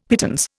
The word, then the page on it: pittance: Indian